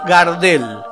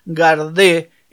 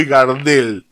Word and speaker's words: Gardel: Gardel. El cantor entiende que es un mejor final para el apellido, que nadie le va a decir nunca más Gardé, enfatizando una pronunciación francesa inexistente,